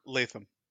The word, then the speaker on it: Latham: Is it Latham or Latham? – Latham. – Latham. Okay, I got it right.